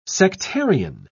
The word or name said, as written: sectarian